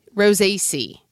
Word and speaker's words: Rosaceae: It's in the family Rosaceae. Did I say that right? – Rosaceae.